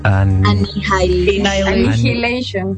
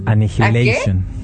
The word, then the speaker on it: Annihilation: nunca sé pronunciar esta palabra. – Annihilation. – Annihilation.